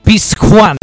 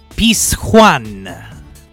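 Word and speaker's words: Pizjuán: Lo stadio di Siviglia, la cui pronuncia vorrei evitare, perché non so se la J è dolce o dura, ma ce lo può dire benvenuti. – Pizjuan. – Come? – Pizjuan.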